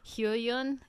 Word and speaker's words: Hyoyeon: I can never pronounce Hyoyeon. – Hyoyeon. Yeah.